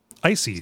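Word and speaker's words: Icey: Spelled like the adjective. – Mm-hmm. Well, except not, because there's an E in there. – Oh yeah, I suppose you're right. Yeah. – Yeah. – Spelled like dicey without the D.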